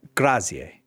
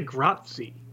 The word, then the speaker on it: grazie: Were you like, a lot of people say grazie. – Yeah. – They just assume the E is silent, like it's French. It's not. It's a different language.